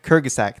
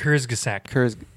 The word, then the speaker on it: Kurzgesagt: Kurzgesagt. – Thank you. I can never say it right. Kurzgesagt? – Kurzgesagt. – Is it Icelandic? – Kurzgesagt.